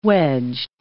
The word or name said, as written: wedged